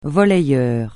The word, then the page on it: volleyeur: francais